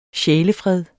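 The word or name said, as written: SJÆLEFRED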